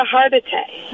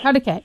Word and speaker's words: Hargitay: Mariska Hargitay. – Hartigay, yeah.